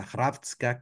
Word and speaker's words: Hrvatska: Tenemos a Hrvatska, que es Croacia, que realmente se escribe totalmente diferente, se los voy a leer, es HRV chica ASKA, Hrvatska, que pues podemos entender que de ahí viene Croacia.